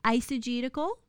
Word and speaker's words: eisegetical: They should never be eisegetical, which means they're taking a concept and they're imposing it on the scripture, right? It's spelled E-I-S, eisegetical, okay?